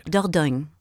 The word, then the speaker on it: Dordogne: Dordogne. – So I just said it pretty close. – Dordogne.